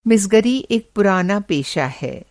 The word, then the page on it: मिसगर: Hindi